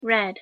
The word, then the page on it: red: English